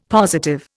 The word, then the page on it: positive: Indian